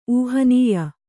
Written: ಊಹನೀಯ

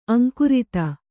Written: ಅಂಕುರಿತ

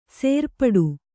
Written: ಸೇರ್ಪಡೆ

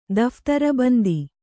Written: ದಫ್ತರ್‍ಬಂದಿ

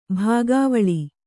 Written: ಬಗೆವೇಳ್

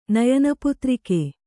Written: ನಯನಪುತ್ರಿಕೆ

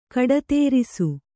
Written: ಕಡತೇರಿಸು

